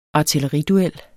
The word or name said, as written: artilleriduel